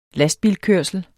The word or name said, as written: lastbilkørsel